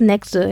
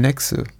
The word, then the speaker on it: Nexø: Hilfe bei der Aussprache Martin Andersen Nexe. Nexe? – Mm, Anders Nexe, ja. – Nexe, Dankeschön. Nexe ist die Insel, ähm, wo er aufgewachsen ist als Jugend, Kind und Jugendlicher.